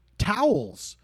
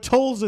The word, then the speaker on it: Towles: I'm not given any pronunciation guide, but A-M-O-R is his first name. T-O-W-L-E-S. Amor Towles. – I always say Towles, but that's just me. – All right. I think I like to say Towles, and that's probably why I'm doing it.